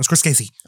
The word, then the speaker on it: Scorsese: Martin Scorsese.